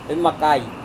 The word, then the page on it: vemakai: Serewen